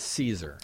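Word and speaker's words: Szczur: And the pronunciation is Caesar. – It's Caesar. – Like, all hail Caesar.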